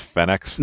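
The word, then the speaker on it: Phenex: Well, I think my favorite detail of this mobile suit is the fact that it's actually pronounced Fenix. – Not quite Spanish.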